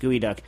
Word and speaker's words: geoduck: It's spelled Geoduck, for those of you that don't. So who the hell knows why it's pronounced gooey duck?